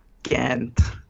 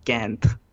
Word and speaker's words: Kainth: It's Kainth. The first two parts were correct. The last was Kainth.